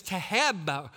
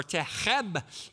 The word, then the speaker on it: Taheb: They referred to him as Taheb, or Taheb, T-A-H-E-B. I believe is how you pronounce it.